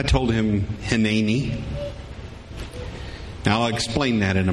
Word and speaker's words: Hineni: If you want to spell it out with dashes in between, it would be H-I-N-N-A-Y with a little apostrophe over the top of that, emphasis on the middle syllable. N-N-E-E, Hineni.